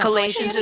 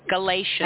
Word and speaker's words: Galatians: Am I pronouncing it wrong? – Galatians, yeah, but okay. You know, Galatians is correct. – Uh-huh. Yeah. – Can you say it for me again? – Galatians.